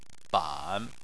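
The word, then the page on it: ban: tone 3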